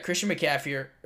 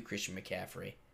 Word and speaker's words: Christian McCaffrey: You've got Christian McCaffrey. butchered that. Christian McCaffrey.